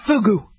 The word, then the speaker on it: fugu: Am I even pronouncing it right? Or is it fuju? Let's just call it fugu. Make sure the U is long sounding, not the short U, okay?